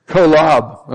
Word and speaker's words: Kolob: How do you, how do you say that? Kolob. Okay. Lobbing. Yeah, it's like, not hardball, but lobbing. Okay, I can do that.